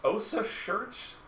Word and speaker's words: OCEARCH: OSA Shirts? It's like ocean and search mushed together.